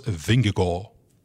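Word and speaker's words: Vingegaard: Niet Lewandowski, Benzema of De Bruyne, maar Jonas Vingegaal. En ja, dat spreek je echt zo uit.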